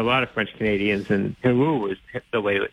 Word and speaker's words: Heroux: They pronounced it Heroux when he was growing up, but he wasn't quite sure. He said some people pronounced it Herox, Herox. Herox is definitely not correct. That yeah. cannot be correct.